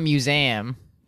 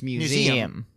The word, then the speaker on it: museum: It's not museum. It's museum. I didn't understand it was three syllables.